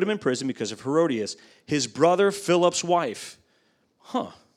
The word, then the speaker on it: Herodias: So, and if I get slipped up, I'm sorry, but I guarantee you that's Herodias. I thought it was something different.